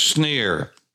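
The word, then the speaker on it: Senir: It's S-E-N-I-R, spelled in English, but it's pronounced Sneer.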